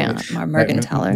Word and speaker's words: Mergenthaler: If it's a TH, it's more of a T sound. – Okay, Mergenthaler. – What is it? Mergenthaler? – Much better, thank you.